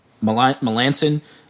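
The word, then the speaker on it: Melancon: Couple of big – so the San Francisco Giants signed Mark Melanson to a four-year contract. When Chris hears this episode, he's going to be proud I pronounced that correctly because I was doing it wrong all week.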